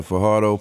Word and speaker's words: Fajardo: I know I'm going to mess up this name, but Fajardo. – Oh, you said it right. – Fajardo. – Said it right. Yeah. – All right, Fajardo, Puerto Rico.